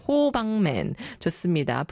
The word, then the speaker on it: 호박맨: 우리 호박맨님께서 호빵맨 유, 저 호박맨인데라고 죄송해요. 영어로 HOBANBNG man 이렇게 나와 있어서 호박맨? 혹시 호빵맨 아닌가? 이러면서 제가 호빵맨이라고 죄송합니다. 우리 호박맨 모닝그루버 이것도 발음 잘해야겠네요. 호박으로 들릴 수도 있어가지고 발음이 쉽지 않아요. 호박맨 좋습니다.